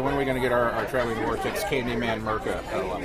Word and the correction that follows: Macra: Oh, the Macra. Macra. I've been pronouncing Macra wrong in the office for the last how many months?